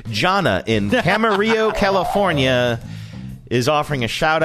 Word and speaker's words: Camarillo: Is the town in California Camarillo or Camarillo? Because yes. sometimes Camarillo. They mispronounce those things, but this one is pronounced correctly, Camarillo, California.